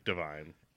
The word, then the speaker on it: Divine: Is it Devine or Divine? – It's spelt like Divine. – Yeah. – So I'm going to say it's Divine, and you just can't pronounce words.